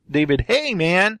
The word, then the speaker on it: David Heyman: David Heyman.